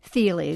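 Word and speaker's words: Thile: Thiele.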